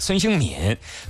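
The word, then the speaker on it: 孙兴慜: 孙兴敏身披的是几号球衣？ 1992年生人啊，韩国的职业足球运动员，前锋位置，现在呢效力于这个哈热刺队。孙兴敏这个敏字哈，他敏啊，正确读音是第三声敏，上面一个敏捷的敏，底下一个心字啊。有人说是孙兴敏，孙兴敏啊，你也可以那么叫啊。正确读音是孙兴敏，多次获得韩国足球的足球先生，还有亚足联颁布的最佳海外球员。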